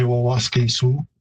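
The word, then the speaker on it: Waskesiu: In Plains Cree, I'd say Waskasu. And in Woods Cree, which is the territory where it's located, Wawaskisu. What's happened there is simply the repeated syllable at the beginning has been lost. So instead of Wawaskisu, it's just Waskisu. The pronunciation in Cree, again, varies at the final from Siu, S-I-W as I would spell it, but you definitely get that I-W sort of merging to more of a Su sound.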